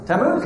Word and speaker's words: Tammuz: Tammuz. Hey, perfect. Good job. No, hey, you got it. So, Tammuz. Now, here's the interesting thing about Tammuz.